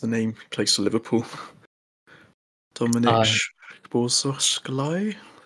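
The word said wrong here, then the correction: Szoboszlai: I said it. – Dominic Jobalai. – There you go, Jobalai. Just butchered it.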